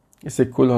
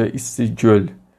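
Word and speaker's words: ایسیکل (Ысык-Көл): ایسیکل حالا به تلفظ قرقیزی به تلفظ ما میشه ایسیگل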